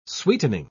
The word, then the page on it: sweetening: British English